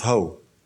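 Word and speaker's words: ho (ὁ): It looks like an O there, but it's pronounced ho.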